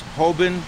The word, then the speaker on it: Hoban: A-N-D-R-E-A Morganti. M as in Mary. O-R-G-A-N-T-E. – I'm Dennis Hoban. H-O-B-A-N. – I'm Shelly Schratz. S-C-H-R-A-T-Z for Schratz.